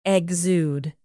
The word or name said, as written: exude